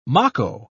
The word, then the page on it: mako: British English